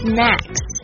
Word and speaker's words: snacks: Okay, number six, 就是 snacks. Snacks，大家要特别注意一下这个发音哦，因为它跟另外一个字很像，是 S N A K E，是 snake，一个是 A 这个微笑音，一个是 snack，当然就是说 snake 是蛇，snacks 是点心的意思。